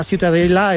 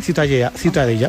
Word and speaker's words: Ciutadella: Para mí la Ciutadella es... ¿cómo se pronuncia? – La Ciutadella. – Ciutadella.